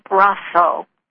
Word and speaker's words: Braco: The name looks like Braco, but it's pronounced Brasso, which means little brother.